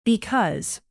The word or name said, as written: because